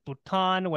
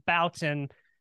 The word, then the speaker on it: Bouton: It's a French last name, so when people see it, they want to pronounce it Bouton, which I'm like, you're not technically wrong to do that because that is the original pronunciation.